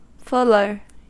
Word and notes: fuller: American English

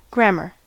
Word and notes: grammar: American English

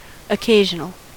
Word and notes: occasional: American English

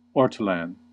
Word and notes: ortolan: American English

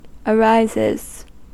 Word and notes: arises: American English

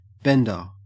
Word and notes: bender: Australian English

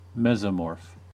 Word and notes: mesomorph: American English

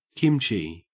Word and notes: kimchi: Australian English

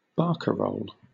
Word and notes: barcarole: British English

